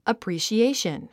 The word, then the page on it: appreciation: əprìːʃiéiʃən; アプリシエィション